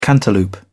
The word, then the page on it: cantaloupe: British English